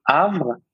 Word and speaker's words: Havre: So Havre.